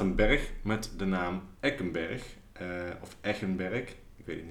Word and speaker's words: Eggenberg: Volgens mij is het Eckenberg, zeg ik dat goed? Echen, ja. Ik, ja.